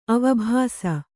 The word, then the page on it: ಅವಭಾಸ: avabhāsa